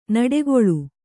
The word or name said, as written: ನುಡಿಗಲ್